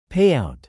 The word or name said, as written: payout